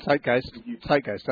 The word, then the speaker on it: Zeitgeist: I believe it's pronounced the Zeitgeist video. – Zeitgeist, uh-huh.